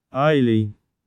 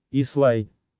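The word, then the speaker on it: Islay: А сдается впечатление, будто название острова, Ислай, произносится Айлей и рифмуется с налей и веселей, что в корне неверно. Первый слог в названии острова действительно Ай, но второй просто Ла. Ничего трудного. Так что давайте избавляться от англизированного произношения, которое в наших краях считается непростительной ошибкой.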